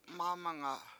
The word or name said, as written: mAmanga7